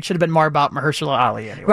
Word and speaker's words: Mahershala Ali: Mahershala Ali. – Mahershala Ali for supporting actor, yes.